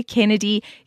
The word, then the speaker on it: Kennedy: It's Susanna Kennedy, spelled S-U-S-A-N-A. Everyone spells my name wrong. S-U-S-A-N-A, Kennedy, K-E-N-N-E-D-Y, like the Kennedys, the president.